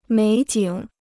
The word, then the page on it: 美景: měi jǐng